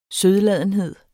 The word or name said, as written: sødladenhed